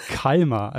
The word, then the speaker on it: Kalmar: Kalmar, also die Stadt wird auf der ersten Silbe betont und nicht der Kalmar.